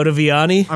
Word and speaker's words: Ottaviani: And his name, the guy who designed it is, uh, I hope I don't mispronounce this, but Guido. Sounds like a Star Wars character. um, Ottaviana? Viana, The, uh, Ottaviani? Uh, Ottaviani? I don't know. – Guido Ottaviani. I'm so sorry that I butchered that so bad.